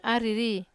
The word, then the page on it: ariri: Wamesa